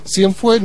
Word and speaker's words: Cienfué: Cienfué no es Cienfuegos, no es que le cortaron la última sílaba, se llama como nombre artístico Cienfué, ha sacado unos 5 discos y es un representante de la nueva canción panameña.